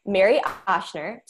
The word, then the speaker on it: Mary Ochsner: um, but it's Mary Ochsner, so it's at M A R Y O C H S N E R on Instagram.